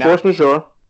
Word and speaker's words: force majeure: I forget how you say this. Majeure? Is that how you say it? The force majeure clause? – Yeah, and force majeure.